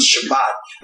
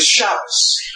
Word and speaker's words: Shabbos: So the pronunciation, you know, when I was growing up, it wasn't Shabbat, it was Shabbos. And, you know, that's because Shabbos is Germanic, it's Ashkenazic, it's European. Shabbat is Sephardic, it's Middle Eastern.